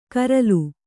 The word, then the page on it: ಕರಲು: karalu